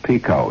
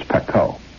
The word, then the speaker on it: Pekoe: It's not pronounced Pico, you know, it's Pekoe. And cut black teas, especially blended, to appeal to families in the New York area.